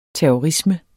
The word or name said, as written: terrorisme